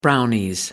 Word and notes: brownies: British English

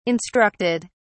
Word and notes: instructed: British English

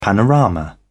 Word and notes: panorama: British English

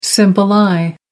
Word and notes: simple eye: American English

